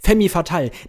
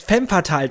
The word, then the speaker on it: Femme Fatale: Und dann sagt er, Femme Fatale. Nein, es heißt Femme Fatale, du hast das falsch ausgesprochen.